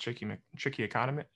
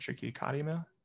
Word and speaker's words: trichotillomania: I'm thinking about instances where, like in OCD, you know, I've treated people with something called trichotillomania. I'm saying it wrong. Trichotillomania. Long word where you pull out your hair. Yeah, trichotillomania. Yeah. I can read it. I can't say it.